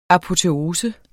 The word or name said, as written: apoteose